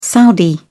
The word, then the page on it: saudi: British English